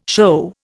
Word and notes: shew: Indian

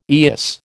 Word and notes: eyas: American English

